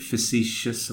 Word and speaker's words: facetious: David Jones was being a bit facetious, if I said it right. And, there you go, got it right.